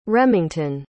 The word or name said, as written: remington